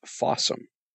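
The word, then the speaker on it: Fawesome: But one that's doing its best in its own way, rival Tubi a little bit, is Fossum. It's spelled just like awesome with an F in the front of it.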